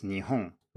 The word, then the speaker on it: Nihon: And in Japanese, it's Nihon, written with the same two characters, but obviously not pronounced the same.